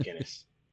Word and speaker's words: Guinness: Yeah, Guinness, yep.